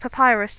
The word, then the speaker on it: Papyrus: Is it Papyrus? Is that how you pronounce it? – Papyrus, that's it.